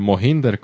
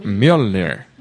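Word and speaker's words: Mjölnir: Ver a Thor luchando, haciendo girar su martillo, el Mohinder, como dice Marmán. – El Mohinder. – Pero se pronuncia Mjolnir.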